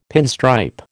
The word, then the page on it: pinstripe: American English